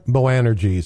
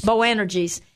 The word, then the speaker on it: Boanerges: Boanerges. – Boanerges.